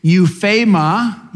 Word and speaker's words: euphema: Euphema, euphema is how it's pronounced, something spoken with cautious reserve.